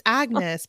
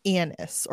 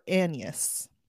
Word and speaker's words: Agnes: It's Agnes, but they pronounce it Annis or Annius.